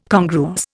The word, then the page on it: congruous: Indian